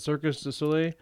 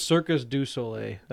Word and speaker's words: Cirque du Soleil: I forgot how you pronounce it. Cirque du Soleil. Okay, I always thought it was Circus du Soleil.